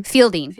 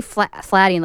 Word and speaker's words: Fielding: And Noel, how do you say his last name? – No. Fielding. – I was going to say Flatting, like Neil.